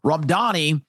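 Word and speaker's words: Mamdani: So as you watch Zoran Ramdani, I always want to say Danny because that's how it's spelled, Ramdani,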